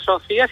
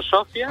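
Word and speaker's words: Sofia: Uno, no se dice Sofía, sino Sofía.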